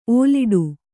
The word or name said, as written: ಓಲಾಡು